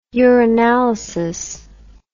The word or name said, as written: uranalysis